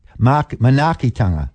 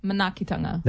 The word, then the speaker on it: Manaakitanga: Did I pronounce that correct? – He Rōpū Manaakitanga. – Manaakitanga. – That's right, which is caring, love, and caring for all people.